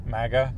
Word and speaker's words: MAGA: Apparently the guy were wearing magma hats. Magma? Is that how you say it? Yeah, magma. MAGA? No, magma is the lava stuff, right? MAGA hats. To be honest, I don't know all of the details of the story, so I'm kind of spewing misinformation.